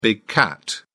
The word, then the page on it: big cat: British English